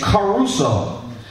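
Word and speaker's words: caruso (κηρύσσω): Because caruso, you know, Enrico Caruso, that's like the way it's pronounced, this Greek word.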